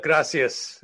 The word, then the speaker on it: gracias: And gracias. That's always important, you know? – Yes. And remember, when you're in northern Spain where they speak Castilian, it's Gracias. The C is pronounced like T-H, Gracias. Gracias.